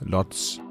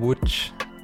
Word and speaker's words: Łódź: Kort før 2020 blev til 2021, skiftede Mikkel Rygaard fra FC Nordsjælland til polske LKS Łódź, eller Łódź, som Rygaard indfører mig i, at det udtales på polsk. – Man siger jo egentlig Łódź, LKS Łódź.